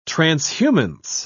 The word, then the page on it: transhumance: British English